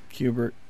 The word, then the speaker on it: Kubert: Kubert.